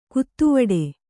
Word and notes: ಗತಿವಡೆ: gati vaḍe; kannada

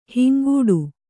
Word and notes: ಹಿಂಗಟ್ಟು: hingaṭṭu

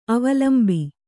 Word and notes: ಅವಲಂಬಿ: avalambi

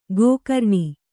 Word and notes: ಗೋಕರ್ಣ: gōkarṇa

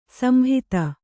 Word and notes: ಸಂಹಿತ: Kannada

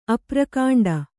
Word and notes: ಅಪ್ರಕಾಂಡ: aprakāṇḍa

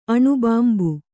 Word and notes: ಅನುಬಿಂಬ: anubimba

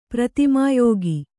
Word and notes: ಪ್ರತಿಮಾಯೋಗಿ: pratimā yōgi

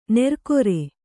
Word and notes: ನೆರ್ಕೊರೆ: nerkore

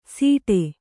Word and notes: ಸೀಟೆ: sīṭe

